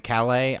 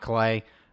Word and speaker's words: Calais: The other notable cuts, Raymond Calais, I think is how they kept pronouncing it, not Calais, and A.J.